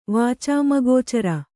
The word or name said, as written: ವಾಚಾಮಗೋಚರ